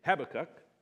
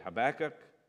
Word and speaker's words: Habakkuk: Some say Habakkuk, some say Habakkuk, and some say Habakkuk, which is a little closer to the Hebrew.